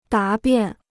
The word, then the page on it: 答辩: dá biàn